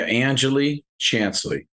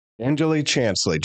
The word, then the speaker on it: Angeli Chansley: Can you pronounce that last name for me? – Yeah, Angeli Chansley. – Angeli Chansley.